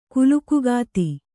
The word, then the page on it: ಕುಲುಕುಗಾತಿ: kulukugāti